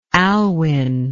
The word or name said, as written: alwin